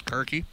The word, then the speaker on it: Karki: Keegan Karki, is that how you pronounce it, Karki? – Yeah.